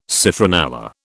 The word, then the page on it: citronella: British English